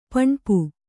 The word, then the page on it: ಪೆಣ್ಪು: peṇpu